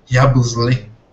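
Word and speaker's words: Yabusele: O, Ia, o Yabusle, como o, o Daniel falou no, no, no último Pod Celtics, que era a pronúncia correta, né? Yabusle.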